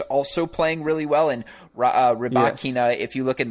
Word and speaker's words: Rybakina: But if I'm not mistaken, it's pronounced Rybakina. – Rybakina, perfect. Rybakina,